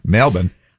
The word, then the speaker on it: Melbourne: Melbourne. – text us. – Oh, sorry. I was just going to say, that's the correct way to say it.